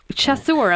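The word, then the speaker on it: chasseur: So you have chicken chassuer, am I pronouncing that correctly? – Yeah, so I figured I'd go for a wee French dish to kick it off, I suppose.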